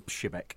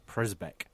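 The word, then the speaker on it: Przybek: Hutchie, you actually asked Mr. Pshibek how you pronounce his name, and Pshibek is a Polish pronunciation. Yep. He also said there is an anglicised pronunciation. – Prisbek. – So if you're in two minds, Prisbek is also acceptable.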